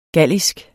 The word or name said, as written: gallisk